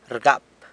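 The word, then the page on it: rgap: San Lucas Quiaviní Zapotec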